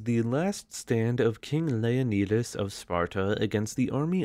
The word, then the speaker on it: Leonidas: Also, yes, I'm using the Greek pronunciation of his name instead of the Americanized version, Leonidas, because I spent my early days in COVID lockdown studying Arabic and Greek, so let me have this one thing.